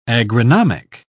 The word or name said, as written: agronomic